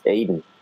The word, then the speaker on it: Aiden: Well, Aiden. Yeah, like the Garden of Eden.